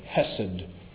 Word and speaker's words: hesed: If you don't know much Hebrew beyond amen, you might want to learn this word, hesed. You can spell it H-E-S-E-D.